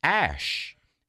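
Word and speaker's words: Æ: Well, tech-savvy Twitter users say in the Scandinavian alphabet, the AE symbol is pronounced ash, and A-12 is apparently the number of a Lockheed spy plane called the Archangel.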